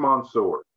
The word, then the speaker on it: Mansoor: Am I even pronouncing that right? Because it almost sounds like I'm making it French. – I think is it Mansoor? – Mansoor. – I think it's Mansoor.